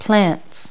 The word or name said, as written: plants